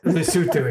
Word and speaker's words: Lesotho: You had a computer programmer from Newport News who went to Soweto. – Lesotho, excuse me. – Lesotho. – Sorry. – We all learned the name of that or how to pronounce that country. – That's exactly. Right. Sorry. It's Lesotho.